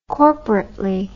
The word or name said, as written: corporately